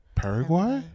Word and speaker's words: Paraguay: Paraguay.